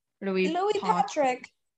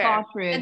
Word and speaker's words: Partridge: Louis Patrick. Patrick. – Patrick. – Well, I know how to say it in, like, Patrick. A British accent, but okay. – Partridge.